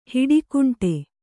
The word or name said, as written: ಹಿಡಿಕುಂಟೆ